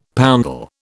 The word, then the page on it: poundal: British English